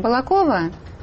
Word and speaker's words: Балакова: Вот мы послушаем из Балакова, Саратовской области. Она вам звонит. Здравствуйте. Простите меня, пожалуйста, за ударение. Балакова? – Балакова, ну, простите.